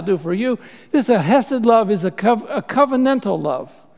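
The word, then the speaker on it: chesed (חסד): In fact, if you want to say it properly, you've got to put like a CH on the front. You've got to go chesed. Try that, chesed, okay?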